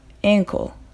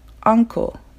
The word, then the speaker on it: ankle: ankle がアンクル uncle のようにならないように注意することです。